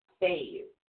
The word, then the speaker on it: Fehu: Now, your first rune is actually spelled F-E-H-U. – F-E-H-U. – But it's pronounced Fayu. – Fayu. – Yes, it's pronounced F-A-Y-H-U. Fayu.